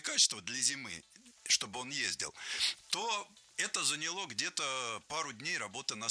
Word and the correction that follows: заняло: Нет, плохо, потому что не заняло, а заняло. Ударение не ставим на окончание ни в коем случае.